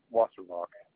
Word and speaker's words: Wasserbach: My name is Chris Wasserbach. – How do you spell that last name? – A-S-S-E-R-B-A-C-H.